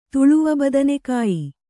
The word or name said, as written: ತುಳುವಬದನೆಕಾಯಿ